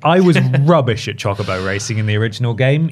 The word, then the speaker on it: Chocobo: Chocobo. – I've purposely mispronounced that. I'm sorry. It's Chocobo racing.